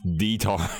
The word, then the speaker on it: Diethard: D-I-E-T-H-A-R-D. – D-I-E? – Yeah, D-I-E. – D-I-E, okay. – It's pronounced D-tard.